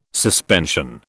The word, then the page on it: suspension: British English